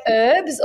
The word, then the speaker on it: herbs: Do you say herbs or herbs? – Oh, herbs. – Like Americans say herbs with an herb. Yeah, no, I'm her. – Okay, good. – Herbs. Yeah, there's a H there. I think that's American.